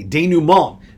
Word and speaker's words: denouement: We are still keeping the social media accounts alive, even though we are coming to the denouement, the sunsetting. – Ooh, that was French? – That was French. Well pronounced, Coach.